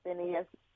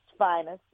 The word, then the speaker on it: Phineas: Charles Lloyd, Phineas, but it's actually pronounced Finus. And if you're in Memphis, they don't know Phineas. They say, oh, you're talking about Finus Newborn.